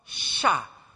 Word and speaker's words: Sha'ar: His name in Hebrew, first name is called Sha'ar. So then second is Yashub. So it literally means, let's start with Sha'ar. I'm not reading the English pronunciation, I'm giving the Hebrew one.